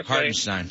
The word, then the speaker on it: Hartenstein: I don't know how to pronounce his last name. – Hartenstein.